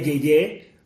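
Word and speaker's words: Yeyé: If you speak Spanish and you have some time in your hands, Ana Julia Yeye, like Anna and Julia and Yeye, like Y-E-Y-E, accent on the last E, like a tilde.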